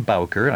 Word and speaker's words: Bowker: J-U-N-O, .com. And my mailing address is John Bowker, I spell my last name B-O-W-K-E-R.